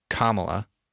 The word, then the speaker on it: Kamala: if Joe Biden becomes president and Kamala, I believe is the correct pronunciation, don't want to be labeled as racist for saying that wrong, because we all know that's a common household name.